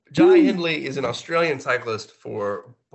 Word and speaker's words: Hindley: Or is Jai – is it Hindy? No, I said it right. Hindley, Jai Hindley.